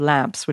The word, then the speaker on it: Lemps: And it came from mainly a vineyard called Lamps, which is spelled L-E-M-P-S.